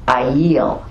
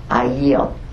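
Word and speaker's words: ayil (אַיִל): The word for ram is ayil. I'm saying that wrong, completely wrong. It's A apostrophe Y-I-L, but it's pronounced ayil, ayil. Okay, I think that's a little better.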